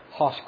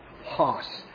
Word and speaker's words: Hoss: And I had a dear friend just this past week tell me I was pronouncing his last name wrong. It is Hoss, sort of like Hoss Cartwright. Hoss. Thomas Hoss.